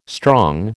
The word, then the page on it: strong: American English